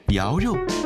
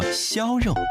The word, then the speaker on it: 肴肉: 在老半斋，肴肉是雪菜微面的最佳搭档。肴肉很多人念作肖肉，是江苏镇江的传统名菜，是淮扬菜系中的代表菜肴。